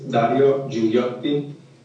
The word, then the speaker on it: Dario Gigliotti: Yes, my name is Dario Filiotti, D-A-R-I-O-G-I-G-L-I-O-T-T-I.